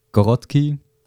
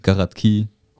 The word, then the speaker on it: Gorodki: Achso, Gorodki praktisch auf Deutsch. Auf der russische Sprachweise Gorodki. Also genau, da betont man das ein bisschen anders.